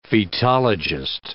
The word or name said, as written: fetologist